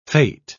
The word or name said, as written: fate